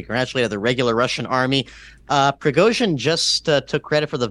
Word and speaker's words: Prigozhin: Yes, well, the Wagner Group is led by a man named Yevgeny Prigozhin, or Prigozhin, for if anybody wants to pronounce it the non-Slavic way.